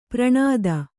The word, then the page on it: ಪೌರನೀತಿ: Kannada